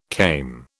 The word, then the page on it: kame: British English